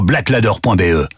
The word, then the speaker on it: Blaklader: Va vite voir sur blackladder.be. Black Ladder, ça s'écrit BLAKLADER.